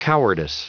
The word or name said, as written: cowardice